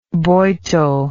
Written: Boito

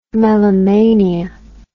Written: melomania

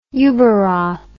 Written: Ueberroth

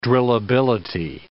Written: drillability

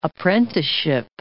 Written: apprenticeship

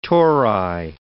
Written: tori

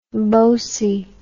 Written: bosey

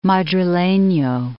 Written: Madrileno

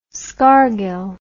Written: scargill

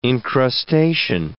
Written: incrustation

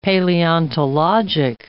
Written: paleontologic

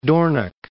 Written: Dornoch

